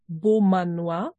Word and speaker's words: Beaumanoir: Also es geht um Anne Beaumanoir.